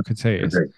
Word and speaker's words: Critias: That's how you say it, Critias.